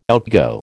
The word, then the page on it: outgo: American English